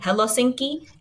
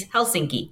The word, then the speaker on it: Helsinki: I keep saying Helsinki. It's Helsinki. It's 3 syllables.